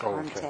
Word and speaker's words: Contech: The first one is going to be Contect Products. – How do you spell that? – C-O-N-T-E-C-H. – Oh, Contect. Okay. – The next is VBROCK, V-I-E-B-R-O-C-K, VBROCK Cells.